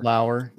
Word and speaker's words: Lower: Is it pronounced Justin Lower or Justin Lower? – Lower. – Lower, yeah.